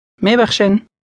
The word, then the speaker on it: میبخشین: میبخشین.